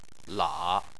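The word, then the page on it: la: tone 3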